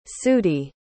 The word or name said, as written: sudie